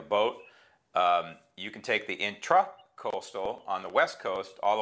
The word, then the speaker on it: intracoastal: And it's technically intra with an A, not E-R, intracoastal, correct? – Correct. – So everybody's mispronouncing it. They say intercoastal because everybody's lazy, right? But intra versus inter, you know, intra means within. So it's an intracoastal.